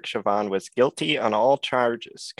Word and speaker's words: Chauvin: Well, for one, I like the way you pronounce his last name, Chauvin. It's like the French word, Chauvin. I say Chauvin, you say Chauvin.